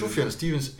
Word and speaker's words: Sufjan Stevens: Hvordan udtaler det? Sofian Stevens. Sufjan Stevens eller Fribytterdrømme.